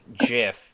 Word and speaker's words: GIF: I say GIF mostly just because I think it sounds nicer. I like the soft G. And people are like, that's not how the G would sound in that scenario. I'm like, you ever heard of a giraffe?